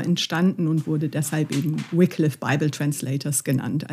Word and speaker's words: Wycliffe: Also es ist tatsächlich Wycliffe oder Wycliffe ähm, und kommt von dem Herrn, der John Wycliffe hieß.